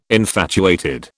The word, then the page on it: infatuated: British English